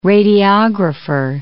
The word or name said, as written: radiographer